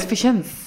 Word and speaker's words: Vicent: Hoe spreek je het uit? Vicent. Vicent? Dat we niet gecorrigeerd worden. Carlos Vicent. – Spreken we het zo uit.